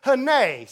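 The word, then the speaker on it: hene (הנה): In the Old Testament, that word behold is a Hebrew word, which you pronounce it hene. Say hene.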